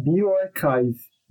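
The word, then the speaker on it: bioRxiv: Mas se chama Bioarchive. Mas se escreve Bio r x i v Bioarchive.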